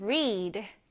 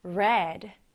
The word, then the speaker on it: read: read는 현재형은 read. 그런데 과거형은 read. 과거 분사형도 read가 됩니다. 스펠링만 보면 모두 똑같은 단어 같지만, 현재형일 때는 read라고 읽고, 과거 분사형일 때는 read라고 읽는다는 점도 꼭 구분해 두세요.